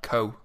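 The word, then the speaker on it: Kou: It's Kuh in German, for example. – That's clever. Only clever people know those kind of things. Do you know, what is it in Luxembourgish? – It's fairly similar. Kuh.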